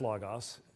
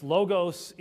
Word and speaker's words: logos: It is logos. It's logos in modern Greek. It's logos in Erasmian pronunciation of ancient Greek. And at least it's not as bad as the Christian school in Moscow, Idaho, which they call logos, which is not possible in any language anywhere.